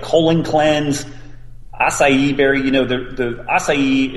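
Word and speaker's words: açaí: Acai, thank you.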